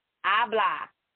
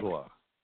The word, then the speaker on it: habla: Habla. The H is silent. – Habla.